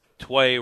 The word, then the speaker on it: Tway: Well, with our show, Tway rhymes with gay.